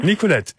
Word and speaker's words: Nicolette: Nicolette, spreche ich das richtig aus? – Nicolette, ja genau. – Nicolette. – Also französisch ausgesprochen, genau.